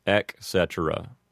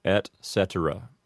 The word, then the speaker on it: et cetera: Another way that people mispronounce it is to take the word cetera and reduce it from three syllables down to two, like cetera. So the only advice I can give for this is to pronounce the T in et clearly, and all three syllables in the word cetera. So that would give you et cetera.